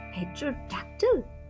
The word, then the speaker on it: pterodactyl: He likes the word pterodactyl. The P is silent like a secret.